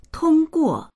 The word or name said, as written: tong1guo4